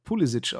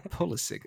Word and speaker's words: Pulisic: Ähnlich wie Fußballer hier Christian Pulisic, den man hier dann aber Pulisic ausgesprochen hat. Aber die Amerikaner wirklich immer Pulisic ist ja, wenn er ein Amerikaner ist, dann wird er wahrscheinlich auch so ausgesprochen. Ja, also beide Secondaries an der Stelle mit schwierigen Matchups auf jeden Fall.